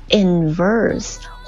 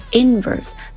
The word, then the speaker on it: inverse: 反比的话呢，他会用到一个形容词 inverse 或者 inverse，它有两种读音呢，要么重音在第一个音节上，要么在第二个音节上。